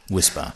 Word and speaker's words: WSPR: Now, WSPR, pronounced Whisper, stands for Weak Signal Propagation Reporter.